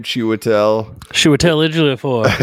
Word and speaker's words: Chiwetel Ejiofor: Chiwetel Ejiofor.